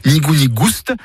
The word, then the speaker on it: nigou-nigouste: Nigou-nigouste, avec prononciation du S originel que l'on retrouve dans le provençal gustus, goûteux, et qui a été remplacé depuis par l'accent circonflexe dans notre orthographe moderne.